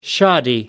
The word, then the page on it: shoddy: American English